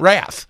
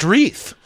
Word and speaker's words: wreath: And so I'm asking questions, I think, oh, this is a Mexico thing, the Advent wrath. He was trying to say wreath. He didn't know it's pronounced wreath.